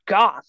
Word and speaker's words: Goff: Goff. Oh, hard G. – Yeah. Like a golf.